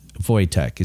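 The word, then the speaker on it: Wojtek: Voitech is how it's pronounced.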